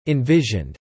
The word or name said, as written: envisioned